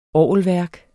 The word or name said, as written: orgelværk